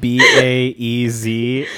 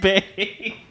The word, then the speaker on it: Baez: Also, it's spelled B A E Z, and it's really funny that Holland pronounced it Baez.